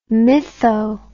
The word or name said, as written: mytho